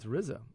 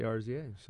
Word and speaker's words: RZA: It's RZA.